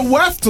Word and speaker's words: weft: Here's what a weft looks like. Anyway, continue. Wefted, wavy, wefted. Weft. There you go. That's helpful. That's how you say it.